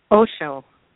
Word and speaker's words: Osho: Is it Oshi or Osha? – Osho. – Osho.